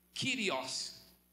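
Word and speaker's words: kyrios (κύριος): Porque na língua original, no grego, é a palavra kyrios, escreve-se kurios, mas pronuncia-se no grego kyrios.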